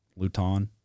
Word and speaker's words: Lutton: Is it Luton or Lutton? Luton? – Yeah, Luton. – Is it really? – I think. – Luton. That's not, Luton. That doesn't sound like a guy that's a very good quarterback, but. – But it does rhyme with crouton.